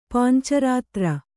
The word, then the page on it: ಪಾಂಚರಾತ್ರ: pānca rātra